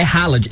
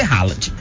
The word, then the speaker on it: Haaland: É Haaland, em português é Haaland.